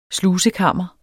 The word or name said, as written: slusekammer